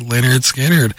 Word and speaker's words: Lynyrd Skynyrd: Tuesday's Gone, pronounced Lynyrd Skynyrd.